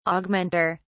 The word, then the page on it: augmenter: British English